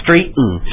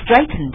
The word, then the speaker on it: straighten: 但是呢你也只要多跟来多念几遍 straighten oh I know why it's so strange because here in English we call this a glottal stop you have T and N next to each other we just eat it we so straighten just like mountain、right?